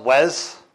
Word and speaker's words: Was: Even though it says was, it's kind of pronounced Wes, you know, like Wes.